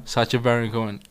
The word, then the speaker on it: Sacha Baron Cohen: Yeah, Sacha Baron Cohen.